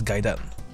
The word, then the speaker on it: Gaiden: Och då ska det uttalas Gaiden.